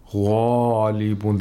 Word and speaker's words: غَالِبُونَ: غَالِبُونَ, the mouth opens vertically when pronouncing غَالِبُونَ, and it's full.